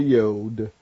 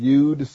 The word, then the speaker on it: Yod: And the letters tonight are going to be the Tet and the Yod. And some people pronounce it Yud, so for those that like to say Yud, it'll be the Yud. Tet is spelled T-E-T-H,